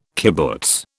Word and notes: kibbutz: British English

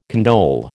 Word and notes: condole: American English

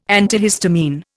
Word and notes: antihistamine: Indian

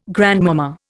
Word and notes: grandmama: Indian

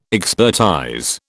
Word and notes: expertise: British English

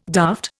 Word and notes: daft: Indian

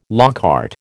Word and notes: à la carte: American English

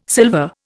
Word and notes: silver: Indian

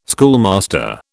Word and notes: schoolmaster: British English

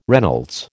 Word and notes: Reynolds: American English

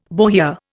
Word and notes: bohea: Indian

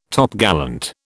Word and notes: Topgallant: British English

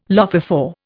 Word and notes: lophophore: Indian

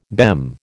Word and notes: BEM: American English

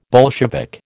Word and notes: Bolshevik: American English

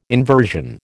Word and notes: inversion: American English

